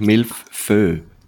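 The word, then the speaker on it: mille-feuille: Milf, eigentlich, ich habe es ein bisschen falsch ausgesprochen, es ist eigentlich Milfö. Also es ist wirklich so ein Milfföier.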